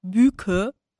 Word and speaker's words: Büke: Ich muss ganz ehrlich sagen, dass ganz, ganz viele mich nicht in die äh, türkische ähm, Bubble verorten, sondern meinen Namen ja immer als Büke aussprechen. Und das klingt sehr nordisch. Das heißt, die denken immer, ich habe so einen skandinavischen Namen.